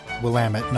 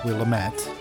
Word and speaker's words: Willamette: Willamette is pronounced Willamette, not Willamette.